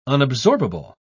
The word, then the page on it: unabsorbable: British English